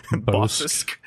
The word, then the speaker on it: Bossk: Bossk. Yeah, it's a syllable because there's two S's. – Bossk. – Bossk. She would be Bossk because there's only one O.